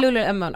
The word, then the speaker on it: Lululemon: Jag vet inte om ni känner till ett företag som lite, nu uttalar, det säkert fel, Lululemon. – Lululemon. Ja, Lululemon.